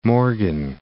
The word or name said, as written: Morgan Hill